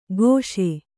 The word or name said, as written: ಗೋಷೆ